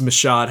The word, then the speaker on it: Michôd: Uh, obviously, David McCod's Michôd, how we pronounce it, his last project wasn't great, but uh, some of his earlier stuff like Animal Kingdom and The Rover were excellent.